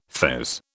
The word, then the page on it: fez: British English